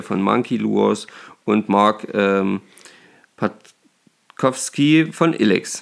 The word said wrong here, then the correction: Patkowski: Patschowski. – Hm. Patschowski, sorry für den falschen Aussprechen.